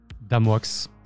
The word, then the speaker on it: DMX: Donc, euh, Red, tu as eu euh, un contact, un, certain fixeur qui se fait appeler euh, Damox. Alors, il écrit ça DMX, mais c'est Damox pour le prononcer.